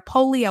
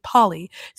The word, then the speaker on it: Polley: Apparently her last name, not Polley, Polly. Sarah Polley, that I was told that's how you pronounce her name because I was saying Polley because it's spelled P-O-L-L-E-Y.